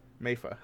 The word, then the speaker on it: Meifa: M-E-I-F-A? Mayfa. – Mayfa, okay. – Yeah, if that's how it's spelled, that should be how... That's the American pronunciation is Mayfa. – That should be the Japanese pronunciation, too.